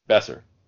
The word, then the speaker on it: Boeser: Nils Ekman, and is it, I don't know how to pronounce, Brock Boeser or Balder? – Brock Besser.